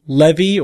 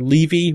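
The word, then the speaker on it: Lévy: So this is called the Levy or Levy walk pattern. Uh, that's L-E-V-Y with an, with an accent on the E.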